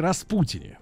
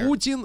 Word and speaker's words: Распутин: Почему американцы не могут говорить Владимир вместо Владимир, как они любят, О, и Владимир. Распутин вместо Распутин. вот как мы все время это слышим.